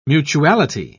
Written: mutuality

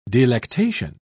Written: delectation

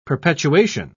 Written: perpetuation

